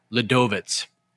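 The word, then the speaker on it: Ľudovít: Wait, how do you say Klein's first name? – Ladovitz. – Ladovitz.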